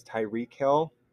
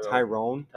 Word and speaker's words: Tyreek: And one thing I didn't mention already is the fact that you guys pronounce his name wrong. It's Tyreek Hill. – Oh, was not Tyrone.